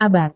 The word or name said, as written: abad